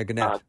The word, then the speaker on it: Gannett: I was having lunch with a journalist recently from a pretty major American newspaper, and this journalist writes for Gannett or Gannett. I'm not sure if I'm pronouncing it correctly, but the big publisher. – Yeah, Gannett.